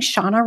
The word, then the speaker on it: Shanna: And I started adding Shanna rhymes with Donna on my Zoom because I wanted to give people the grace.